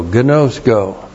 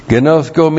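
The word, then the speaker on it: ginosko: G-I-N long O-S-K long O. Gnosko. Gnosko means to come to know.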